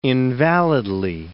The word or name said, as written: invalidly